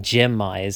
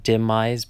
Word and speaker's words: demais: It starts with a D, but it's actually said Gemize, so it looks like Demize, but it's Gemize.